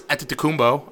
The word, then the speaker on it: Antetokounmpo: And Team Giannis, it's obviously Giannis Antetokounmpo.